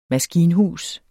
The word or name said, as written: maskinhus